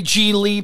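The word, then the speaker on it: Gigli: Lee, Larry G. Lee, pronounced like really.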